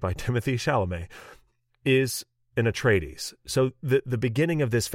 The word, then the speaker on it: Atreides: and I'm going to say this wrong, Atreides. Was that right? – That's totally right.